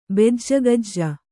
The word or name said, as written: ಬೆಜ್ಜಗಜ್ಜ